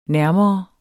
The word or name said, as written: nærmere